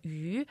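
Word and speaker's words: yu (鱼): So the name for fish in Chinese is yu. Yu, as a character, the pronunciation can also refer to surpluses.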